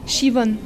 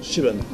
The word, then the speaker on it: Siobhan: Est-ce que j'ai bien prononcé Siobhan Owen? – Siobhan. – Siobhan. – Ouais. – C'est du gaélique? Comme Siobhan en fait. Yvonne et Cheux avant.